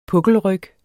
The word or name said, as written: pukkelryg